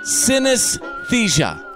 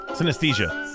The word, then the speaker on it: synesthesia: There is a woman in Scotland named Julie McDowell who has synesthesia. – Synesthesia. – Synesthesia, yeah. – Yes, didn't I say that? Well, everybody always likes to pronounce the word. Synesthesia? – Synesthesia. – Yeah, kind of like anesthesia.